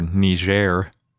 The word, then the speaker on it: Niger: Specifically, they found it in Niger, or Niger, which is apparently the right way to say it.